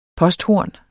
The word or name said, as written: posthorn